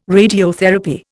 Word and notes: radiotherapy: Indian

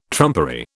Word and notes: trumpery: British English

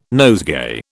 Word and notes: nosegay: British English

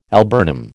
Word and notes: alburnum: American English

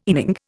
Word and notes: inning: Indian

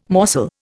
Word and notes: morsel: Indian

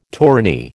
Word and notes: tourney: American English

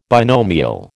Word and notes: binomial: American English

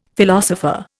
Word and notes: philosopher: Indian

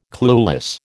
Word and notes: clueless: American English

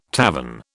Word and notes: tavern: British English

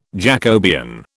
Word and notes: Jacobean: British English

